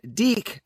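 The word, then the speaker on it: DIC: The first 3 seasons were on Deke, as I've learned. Deke Enterprises was the production company, not Dick, as I was pronouncing it the entire time.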